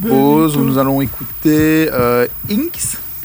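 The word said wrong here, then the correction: INXS: In excess.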